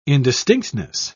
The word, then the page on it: indistinctness: British English